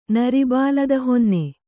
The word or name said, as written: ನರಿಬಾಲದಹೊನ್ನೆ